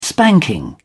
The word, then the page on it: spanking: British English